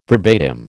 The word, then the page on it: verbatim: American English